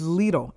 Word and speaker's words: Lidl: Is it Lidl? – It's Lidl. L-E-E-D-I-L.